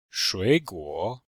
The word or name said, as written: shui3guo3